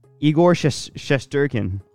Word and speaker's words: Igor Shesterkin: How do you say the guy's name? – Igor Shesterkin.